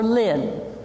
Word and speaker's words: Lynn: The L is for Lynn, which according to my birth certificate is spelled L-Y-N-N.